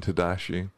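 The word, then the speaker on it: Tedashii: Shay Lynn and Tedeschi.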